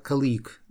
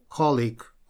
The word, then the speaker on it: colleague: Вот это слово, его очень часто путают, говорят colleague. Нет, это такого варианта нет. Есть только colleague, colleague. Всегда ударение на первый слог.